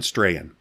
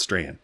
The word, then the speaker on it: Strachan: The two podcasts I would go to, Owen Strahan. Let me spell it for you. Strachan. Yeah, it's Strahan. Owen Strahan.